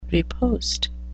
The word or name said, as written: riposte